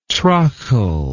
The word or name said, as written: trachle